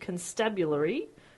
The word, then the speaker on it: constabulary: He re-enlisted as a corporal and was deployed to Haiti for 5 years to train the newly formed constabulary. It's always a hard word. Did I say it right? – Yeah. – You nailed that.